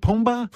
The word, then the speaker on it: Pumbaa: Pumbaa? – Pumbaa's right.